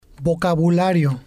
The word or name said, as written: vocabulario